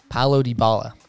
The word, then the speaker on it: Dybala: Paulo Dybala.